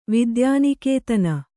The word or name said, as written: ವಿದ್ಯಾನಿಕೇತನ